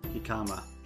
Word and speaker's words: jicama: and we had the last of the Mo Himaka? – Jicama. – Jicama? – No. – Is that how you say it? Yeah.